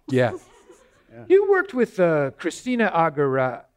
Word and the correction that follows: Aguilera: Aguilera. – Christina Aguilera.